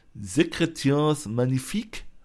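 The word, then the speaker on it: Sécrétions Magnifiques: Und diese herrlichen Körpersäfte von Etat Libre d'Orange, das französische Wort bedeutet, also wird ausgesprochen, Secretaires Magnifique. Das, ja, das, das ist halt auch dabei, ja.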